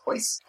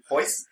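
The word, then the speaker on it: Royce: which was Hoist. – Well, you need to know that every son's name starts with an R, and it's pronounced H.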